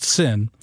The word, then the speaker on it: Seine: Paris spent, get this, the River Seine, which I think is interesting because it's pronounced sin.